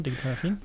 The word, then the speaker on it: Digital Affin: Du betreibst auch einen eigenen Blog, Digital Affin wenn ich es richtig mhm, ausspreche. Genau, Digital Affin.